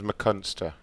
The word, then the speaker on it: McCunster: The last name's McCunster. – How do you spell it? – M-C-C-U-N-S-T-E-R.